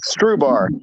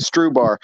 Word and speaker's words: Strubhar: So my last name is Strubhar. It has an H in it, but the H is just a hitchhiker. So we just say it Strubhar, kind of like rhubarb, but it's just Strubhar, and like straw and a bar, basically.